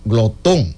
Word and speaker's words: glotón: Y su nombre científico proviene del latín y significa glotón, así, con acento en la O.